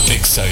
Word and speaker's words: Exa: Contraseña XHFR, Exa FM, 100.5 FM. ¿Dónde? XFM 100.5, una estación de Grupo Radiofónico B15. No se dicen éxitos, se dice EXA.